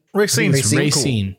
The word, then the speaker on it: Racine: Racine.